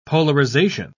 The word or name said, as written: polarization